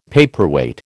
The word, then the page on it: paperweight: American English